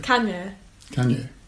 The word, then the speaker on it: Kanye: How do we pronounce Kanye? – Kanye. Yeah, that was fine.